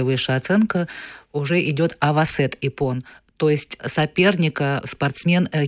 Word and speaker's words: Иппон: Иппон тоже его как бы не говорят Иппон, он как Понятно. Ипон.